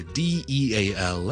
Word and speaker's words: deal: 一单交易，我哋 D E A L 咧个发音有少少似我哋头先 deal 雕草个 deal 嘅，OK？但系我个响音系一个 E 音嚟嘅，听落去就变成 deal。